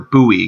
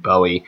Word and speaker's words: Bowie: You also got the Erie Seawolves and the Bowie Bay Sox of the Tigers and Orioles, respectively. Or Bowie, excuse me. Bowie, Maryland. It's spelled Bowie, like David Bowie. And I recently learned it was pronounced Bowie, so there's your fun fact for random towns and how you pronounce them.